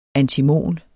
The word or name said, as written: antimon